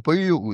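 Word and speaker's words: Beinwil: Also, Bainwil spricht man Böju aus. Wie kommt man von Bainwil auf Böju?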